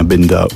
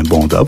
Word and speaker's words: Benda: de Julien Benda ou Julien Banda, prononcez-le comme vous voulez.